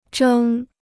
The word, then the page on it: 蒸: zhēng